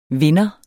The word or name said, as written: vinder